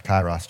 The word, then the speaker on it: Kairos: I think you guys say Kairos. Yeah, it's pronounced Kairos.